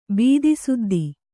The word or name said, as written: ಭೂತಸಿದ್ಧಿ